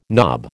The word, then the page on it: knob: American English